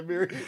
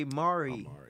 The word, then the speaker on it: Amiri: I just heard it's rapper say Amiri jeans and no. It's a clothing line. – I've heard somebody say Mari. – They said it wrong too.